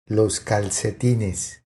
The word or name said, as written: Los calcetines